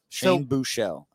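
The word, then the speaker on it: Buchele: It seems like there's an opportunity to say his name correctly. – Yeah, there you go. – Shane Buchel. I said Buchelli on like two shows, and I couldn't.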